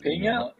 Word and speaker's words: Peña: Pena'yı gördük. Penya mı? Penya mı şimdi? Nasıl telaffuz edeceğiz? – Penya yani Penya.